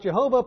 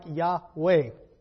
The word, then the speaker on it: Yahweh: Sometimes mispronounced Jehovah, probably originally pronounced something like Yahweh.